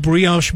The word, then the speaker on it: brioche: Toasted brioche bun.